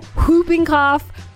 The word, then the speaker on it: whooping: You can just say whooping cough and it's okay. – I like to pronounce the H. I don't like to leave any of the letters out. – Whooping cough.